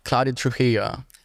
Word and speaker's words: Claudia Trujillo: She's an actor, Claudia Trujillo.